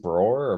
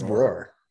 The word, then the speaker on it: broar: Is it broar or bro? – It's broar. – Roar. I always see them saying it like bro, and it has like O-A-R-R afterwards.